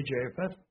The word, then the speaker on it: Japheth: God shall enlarge Japheth," as the way it's pronounced in Hebrew, we would say Japheth, "and he shall dwell in the tents of Shem, and Canaan shall be his servant."